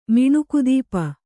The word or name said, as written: ಮಿಣುಕುದೀಪ